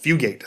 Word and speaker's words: Fugate: Fugate. That's how you say it.